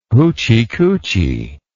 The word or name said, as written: hootchy kootchy